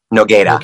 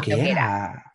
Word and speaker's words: Nogueira: This is Anna Nogueira. Is that how you say it? – Nogueira. – Nogueira. – Like a Brooklyn guy saying, I got Nogueira. That's how she describes it.